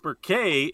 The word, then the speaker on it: Murchadh: Because Merkay is spelled in the Gaelic way, Gallic way, M U R C H A D H.